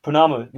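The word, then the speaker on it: pounamu: Is that right, Pinami? Is that, am I saying that correctly? – Pinami. – Punamu. Make sure everyone carries their Punamu for George.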